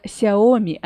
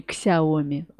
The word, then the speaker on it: Xiaomi: Говорить правильно Xiaomi, а не Xiaomi.